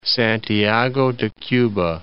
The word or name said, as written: Santiago de Cuba